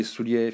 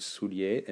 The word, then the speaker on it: Soulier: So Didier Soulier, if you don't know how to spell and you're an imbecile, I'm kidding. It's D-I-D-I-E-R. And my surname is Soulier. And that's S-O-U-L-I-E-R. Okay? D-I-D-I-E-R. S-O-U-L-I-E-R.